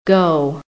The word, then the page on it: go: American English